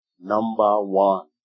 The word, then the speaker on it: Numbawan: Number One, not Number One, N-U-M-B-A-W-A-N, Number One, that's the way it's pronounced, alright?